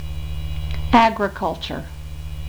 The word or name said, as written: agriculture